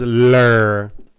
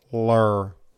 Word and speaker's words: Ler: Is L-E-R. – If you want to get fancy, L-Y-R. – L-Y-R. Lur. And you have to say it like that. Lur. You can't say lur. Lur.